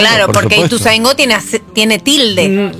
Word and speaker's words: Ituzaingó: No sé, para mí es Ituzaingó. Tiene acento en la O. – Es Ituzaingó, sí. – Pero le dicen Ituzaingó.